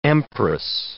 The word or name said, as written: empress